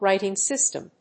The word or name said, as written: writing system